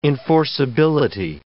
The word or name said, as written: enforceability